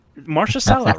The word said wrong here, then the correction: Mahershala: No, it's Mahershala.